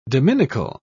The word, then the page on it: dominical: British English